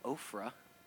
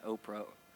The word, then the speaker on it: Ophrah: One day the angel of God came and sat down under the oak in Ophrah, not Oprah, that belonged to Joash the Abizurite.